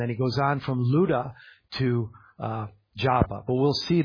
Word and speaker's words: Lydda: It's spelled Lydda in the English, but it's actually Ludda and pronounced Luda. Luda.